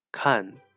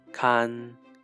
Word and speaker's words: 看: Now that's it for today, where we looked at the pronunciation of the word kan. In the fourth tone, it means to look, watch, or read, and in the first tone, kan, it means to look after.